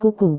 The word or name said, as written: kuku